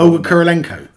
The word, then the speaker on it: Olga Kurylenko: Is it Olga Kurylenko? – I don't even know why you bother. – I don't know why. – Olga Kurylenko.